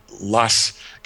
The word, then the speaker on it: loess: There is a kind of soil dust in Judea that's called loess. or probably a better pronunciation is LOESS, L-O-E-S-S.